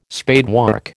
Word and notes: spadework: American English

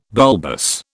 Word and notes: bulbous: British English

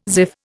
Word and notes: ziff: Indian

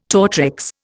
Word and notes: tortrix: Indian

